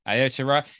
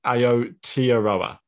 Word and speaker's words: Aotearoa: Spelt Aotearoa? – Aotearoa. Yeah, because there's no clear, we don't know where the stress is in this kind of phonetic transcription. – And Hammy has said pronounced Aotearoa, I think.